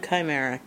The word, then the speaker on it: Khymaera: And I'm Chimera, Khymaera. And I spell that because it doesn't sound exactly like it's spelled.